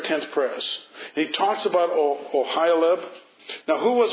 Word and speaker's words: Oholiab: How do you say his name? Oholiab? Okay.